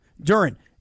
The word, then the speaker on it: Duren: And I've got Jalen, and I hope I'm pronouncing this kid's last name right. Duren, is that how you pronounce his last name? – Duren. – I've got Jalen Duren tonight to get more than 9.5 rebounds.